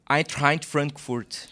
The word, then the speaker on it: Eintracht Frankfurt: no, vamos no Werder Bremen primeiro, qual a pronúncia que tu fez? Werder Bremen. – E, de... e emprestado pelo? Eintracht Frankfurt. Que fantástico.